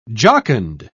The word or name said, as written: jocund